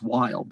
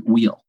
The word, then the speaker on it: Weil: And he said to my father, he says, you know, our last name is Weil, but they pronounce it Weil, Billy Weil.